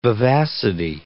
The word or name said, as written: vivacity